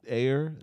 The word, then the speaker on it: Ayer: And then David Ayer, that's how you pronounce his name.